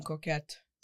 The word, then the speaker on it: coquette: We're going to start embracing Coquette.